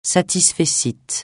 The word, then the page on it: satisfecit: francais